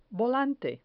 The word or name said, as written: volante